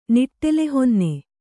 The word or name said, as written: ನಿಟ್ಟೆಲೆಹೊನ್ನೆ